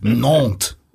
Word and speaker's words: Nantes: This is the average goals per game of, this is my pronunciation, my French means, Nantes. Is that right? – Strong.